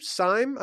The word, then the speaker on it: Syme: And Rachel Syme, I don't know how you say it, Rachel, S-Y-M-E on Twitter. – Got to be Syme.